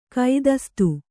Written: ಕುತ್ಸಿತೆ

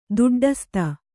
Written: ದೊಡ್ಡಸ್ತು

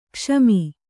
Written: ಕ್ಷಮ

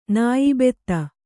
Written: ನಾಯಿಬೆತ್ತ

